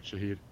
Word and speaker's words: Shahid: Shahid Khan. How do you say his first name? – That's right, Shahid.